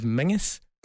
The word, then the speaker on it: Menzies: And for all those outside of Scotland as well, for some inexplicable reason, we pronounce the word Menzies as Mingus.